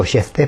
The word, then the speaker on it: Jefté: Es Jefté, se escribe con J. Yo digo Jefté porque porque soy cajetilla, no sé.